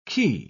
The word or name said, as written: quay